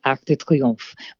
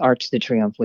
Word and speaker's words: Arc de Triomphe: Arc de Triomphe, which is Arch de Triomphe, which is the way Americans say it.